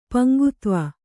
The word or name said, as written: ಪಂಗುತ್ವ